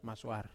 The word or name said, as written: maswar